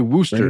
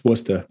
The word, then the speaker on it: Worcester: I'm going to say Worcester. Right. Worcester, Massachusetts. I think that's how you say it. – Oh, no. Don't, because you're going to get crap for that. It's Worcester.